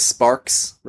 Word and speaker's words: Sparks: It is Sparks, right? Yeah. – Yes, like a welder, when you touch metal and you see the flash, you make the sparks.